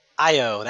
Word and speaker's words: Io: Io, that's how you pronounce it.